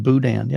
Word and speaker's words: Boudin: Pronounced boo dan. Boudin, I think. Boudin. – Let us know in the chat if you ever heard of that. Boudin. – Yeah, Anthony Perez right there. – Oh, there you go. Pronounced Boudin. Yep, Boudin. That's it.